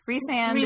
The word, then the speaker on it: Rhysand: And Rice, is it? How do you say his name? Rice, Reese, Reese, Reese Rhysand, is, yeah.